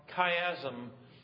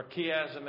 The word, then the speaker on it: chiasm: We have here this what's called inverted parallelism or chiasm. Or chiasm, as some pronounce it.